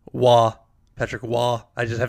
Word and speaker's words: Roy: My notes say Patrick Roy, so that is how I'm pronouncing it. – It's spelled, but it is pronounced Wah.